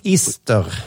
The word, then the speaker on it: ister: Svarta bär och ister. Ister. Och då, med, då är betoningen på ister.